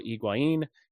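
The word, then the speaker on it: Higuaín: So the comeback, there were goals from Federico Higuain. Don't believe Adrian Healy when he pronounces that first name with an R in it or with two R's, I guess.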